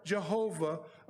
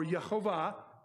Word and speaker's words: Jehovah: to make atonement for you before Jehovah, or Yehovah, your God. You can say it either way. I'm trying to learn to not pronounce the J, because they didn't use the dang J.